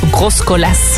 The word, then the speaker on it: Groscolas: Op 405, jij kan het zo mooier zeggen, hij maakt een Pierre Groscolas. Zo, hè? Eind is, zou ik nu niet uitspreken.